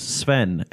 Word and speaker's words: Sven: So I am Sven Jansson. – Can you spell that last name? – Yes, Sven. S-V-E-N. – Jansson. – Jansson is J-A-N-N-S-O-N.